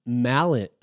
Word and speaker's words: Malian: When Robert Palmer spotted Lauren Malian rhymes with alien.